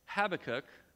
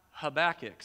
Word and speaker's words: Habakkuk: We are going to be in the book of Habakkuk, not Habakkuk. So if I fumble over that name, that is exactly why.